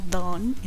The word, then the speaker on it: Dawn: este es D-A-W-N, pero se pronuncia Don, entonces Don y Don.